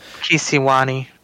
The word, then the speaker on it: Kisiwani: I forget how to pronounce Kilwa Kiwanasi, whatever the Kisiwani.